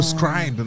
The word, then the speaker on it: Scribe: Mas para mim, o destaque vai pro Kindle Scribble. Kindle Scribe, não sei a pronúncia. – Scribble, que é... – Soletra pra ajudar. – É verdade, se escreve S-C-R-I-B-E.